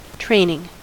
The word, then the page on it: training: American English